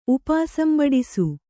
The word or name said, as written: ಉಪಾಸಂಬಡಿಸು